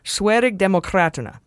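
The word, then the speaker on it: Sverigedemokraterna: And finally, I botched the pronunciation of the Swedish Democrat Party, which is super right wing, while we were talking about global authoritarianism. So I'll give it another go and say Sverig Demokraterna.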